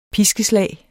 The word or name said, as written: piskeslag